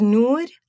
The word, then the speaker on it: Noor: My name is Noor, as long as you can pronounce it. But if you ask me if I said, oh my God, nude, like naked, then please call me Nora, because I'm not answering that question again, like ever.